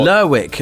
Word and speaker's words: Lerwick: Is it Lerwick or Lerwick? Or Lerwick, Lerwick, if you're talking Shetland Islands. – Lerwick of Shetland, which I believe is that the northernmost island in Britain? – Correct, yes.